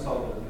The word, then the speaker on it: solder: You ask if somebody soldered. – Yeah, you don't say the L, do you? It's solder.